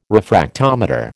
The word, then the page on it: refractometer: American English